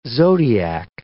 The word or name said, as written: zodiac